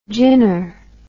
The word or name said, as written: ginner